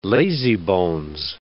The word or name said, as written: lazybones